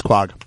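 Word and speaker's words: Quogue: People on the island, we laugh that people can't pronounce the names of the towns. It's Quag.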